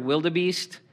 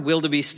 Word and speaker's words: wildebeest: Okay, the word wildebeest is actually an Afrikaans word, and I pronounce it like an American now, which kind of every time I say it, it bothers me. The word is wildebeest.